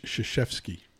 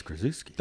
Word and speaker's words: Krzyzewski: I'm watching college basketball, and they were talking about Coach Krzyzewski. And they put his name up, and I'm like, that's Krzyzewski.